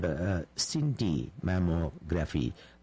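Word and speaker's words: scintimammography: There is another new imaging test called CINTI mammography. CINTI is spelled S-C-I-N-T-I, CINTI mammography, also known as molecular breast imaging.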